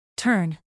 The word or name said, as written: tern